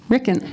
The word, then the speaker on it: Ricken: Did I say that right? – Rhymes with chicken. – Ricken.